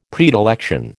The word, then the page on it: predilection: American English